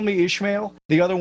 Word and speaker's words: Ishmael: Did I say that right? Ishmael? – Call Me Ishmael.